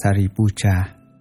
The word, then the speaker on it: Sariputra: Ngài Xá Lợi Phất, Ấn Độ đọc là Sariputra, chữ Hán là thân tử.